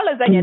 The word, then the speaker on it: lasagna: So, you know, when you spell lasagna, hmm. there's a G, but you don't pronounce that G. So people you know, G's moving silent.